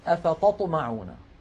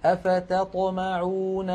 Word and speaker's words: أفتطمعون: فكثيرا ما نسمع من بعض المبتدئين يقولون أفتطمعون، أفتط فينطقون طائين، طاء بدل التاء ثم يطلقون الطاء الثانية. هنا لابد للقارئ من الانتباه وبيان ترقيق التاء الأولى فيقول هكذا أفتطمعون أن يؤمنوا لكم.